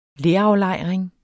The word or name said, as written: leraflejring